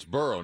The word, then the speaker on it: Burrow: Well, yeah, but hey, please learn how to pronounce his name. It's Burrow, not Burrow.